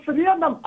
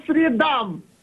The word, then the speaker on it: средам: Правильно говорить не по средам, а по средам. Запоминай правильное ударение.